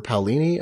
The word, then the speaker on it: Paolini: And the Aragon series, which I have not read, by Christopher Paolini, I think is how you pronounce the last name, right? And he's, yep.